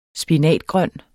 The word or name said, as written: spinat